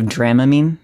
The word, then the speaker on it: Dramamine: No pronouncing that drug with a long A on the first syllable. – Instead of Dramamine? – Yeah.